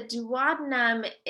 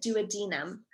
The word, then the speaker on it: duodenum: So the duodenum is, and that's how we pronounce it in the US, but I've had some professors here from the UK and they call it the duodenum. So there's multiple ways to say that.